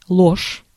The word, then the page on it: mensonge: France; [mɑ̃.sɔ̃ʒ]